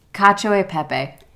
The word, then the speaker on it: Cacio e pepe: Cacio e pepe.